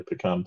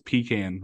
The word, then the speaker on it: pecan: I mean, I could probably eat my words here and get some hate for this, but their pecan pie doesn't look terrific, so I'm probably also going coffee. – Fair enough. And you said it wrong. It's pecan whenever you're in the Carolinas.